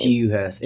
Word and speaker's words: Dewhurst: Dewhurst. I almost said with a J sound, but morning dew.